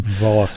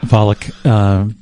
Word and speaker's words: Volokh: Volokh. – Volokh.